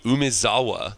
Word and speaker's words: Umezawa: We have Tetsuko Umezawa Fugitive. Yes, that's the same pronunciation as on Umezawa's Jite.